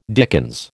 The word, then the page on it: dickens: American English